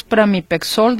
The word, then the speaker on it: Pramipexol: Sí, lo pronuncié bien entonces. Es Pramipexol de 1 miligramo.